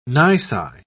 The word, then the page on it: nisi: British English